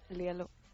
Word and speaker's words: Lelo: ¿cómo se dice Lelo en sueco? Lielo. Lielo. – Hoy. Suena, suena bastante más bonito.